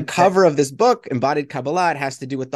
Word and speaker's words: Kabbalah: So I pronounce it Kabbalah. But Kabbalah. I'm open to different pronunciations. That's sort of a more modern Hebrew pronunciation. Kabbalah is more Ashkenazi, from sort of the Eastern European Jewry.